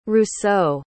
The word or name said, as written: Rousseau